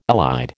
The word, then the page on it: allied: American English